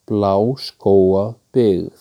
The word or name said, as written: Bláskógabyggð